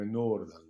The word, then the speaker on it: Nordahl: come Nordal, come lo chiamava lui con la pronuncia giusta, come Nordal non si, non si è mai più visto, fidati, che probabilmente non si è, più mai, si è mai più visto davvero.